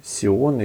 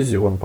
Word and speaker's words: Зион: В первый день парк Сион или Зион, там по-разному читается в русскоязычных источниках. Но вообще Зион, по-моему.